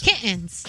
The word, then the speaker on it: kittens: Kittens. How would you say it? – How do you kittens? You gotta get the T in there. Kittens.